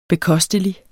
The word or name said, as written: bekostelig